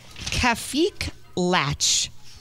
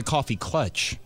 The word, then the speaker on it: Kaffeeklatsch: Oh, that's a coffee clutch. – Coffee clutch. Is that what it's called? – What do you call it? Kalach? – It says Kafik It's spelled K-A-F-F-E-E-K-L-A-T-S-C-H.